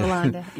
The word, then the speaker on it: Hollande: You know, I don't think you pronounce the H over there. – Hollande, yeah.